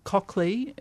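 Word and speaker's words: Colclough: So if people are interested, it's called Cockley is the name of the family, and that's the name of the garden. But that's not how it's spelt. It's spelt C-O-L-G-O-U-G-H or something like that.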